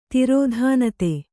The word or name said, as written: ತಿರೋಧಾನತೆ